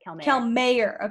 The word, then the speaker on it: Calmeyer: Calmeyer.